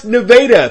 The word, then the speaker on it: Nevada: Nevada High School, yes, that's pronounced Nevada.